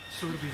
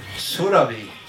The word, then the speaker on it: Surabhi: What's the proper pronunciation of, as we Westerners will say it, Surabhi? – Surabhi. – Surabhi.